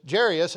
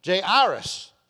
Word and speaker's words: Jairus: Well, they didn't say that, but they just pronounce his name differently when you listen to the computer. Jairus. And so if you want to call him Jairus, knock yourself out.